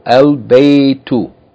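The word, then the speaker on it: al-baytu (البيت): Ba is not a sun letter, and therefore yani should be pronounced normal, al-baytu.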